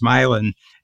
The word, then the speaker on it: Milan: It was called Milan, same as Milan, Italy, but it's pronounced Milan.